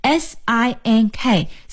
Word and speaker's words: sink: 当然这个厨房里还有一些其他的设施，比如说我们有这个洗碗的洗碗槽，那么这个水槽还有洗碗槽我们就可以叫 sink, S-I-N-K, sink。注意啊，这里呢是 S 的发音，要发 S 的音，也就是这个舌尖呀不用伸出来，一起再来试一下 sink。